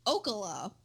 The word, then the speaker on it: Ocala: But on September 7th, a man was arrested for a burglary outside of Gainesville in Ocala. Is how I think that's pronounced, and it probably is not. No. O-C-A-L-A. – Sounds right.